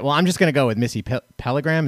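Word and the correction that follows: Peregrym: Is that how you say her name? – Peregram.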